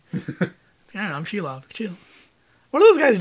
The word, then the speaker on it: Shelob: How do you pronounce it? – Shelob. – Shelob.